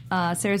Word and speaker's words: Sarah: You can find me at Sarah the Rebel everywhere. Sarah spelled with an H, as God in the Bible intended.